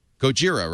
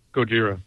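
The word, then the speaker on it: Gojira (ゴジラ): How do you say Godzilla in Japanese? Chat room? Gojira, right? – Chat room says Gojira.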